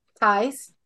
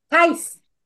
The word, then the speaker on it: Theis: We're speaking with Mandy Theis. – Theis. – It's that German pronunciation gets people every time. – Theis.